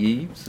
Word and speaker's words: Yves: Yeah, and that's spelled Y V E S. – Yes. – The French way.